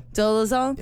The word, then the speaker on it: Dolezal: How do you say her name? – Dolezal? Yeah. – Yeah, you're right.